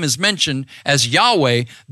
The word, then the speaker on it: Yahweh: And that name in Hebrew is spelled Yod-Heh-Vav-Heh. And we think it is pronounced Yahweh.